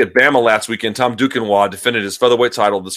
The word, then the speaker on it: Duquesnoy: You got to see this kid, Tom Duquesnoy.